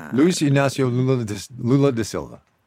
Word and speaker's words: Luis Inácio Lula da Silva: Luis Inácio Lula da Silva.